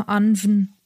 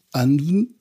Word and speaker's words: Annwn: nee, wie spricht man das aus? – Ja, Anven. – Anven, also A-N-N-W-N, viel Spaß beim Aussprechen, oder das irische Tirnanog, Land der ewigen Jugend.